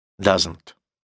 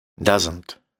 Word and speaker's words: doesn't: То есть там в серединочке есть «с». Doesn't. Просто произносится как «з». Doesn't – это «не» для настоящего времени.